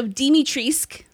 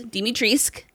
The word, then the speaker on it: Dimitrescu: So Dimitrescu, yes, Dimitrescu, according to Capcom, the U is silent.